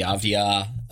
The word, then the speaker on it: Avdija: but whether it's Denny Avdija, I hope I'm finally pronouncing that right. Is that correct? Can we get a pronunciation check on that one? – No, you got it. Yeah, that's right.